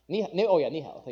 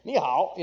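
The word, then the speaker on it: nǐ hǎo: I would say, nanhai, right? Am I saying that right? No. Nihao, thank you.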